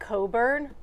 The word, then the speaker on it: Cockburn: Listen, in this story, I know it's pronounced Coburn, but her last name is Cockburn. It just is.